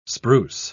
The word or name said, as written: spruce